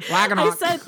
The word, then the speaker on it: Ragnarok: And I noticed, well, if you watch Thor, Ragnarok, oh, I have to say it like that or I'm going to say it wrong Ragnarok. – I said Roanoke, Thor Roanoke. – My favorite season of American Horror Story. – I said Thor Roanoke and everyone dragged me for like 3 days.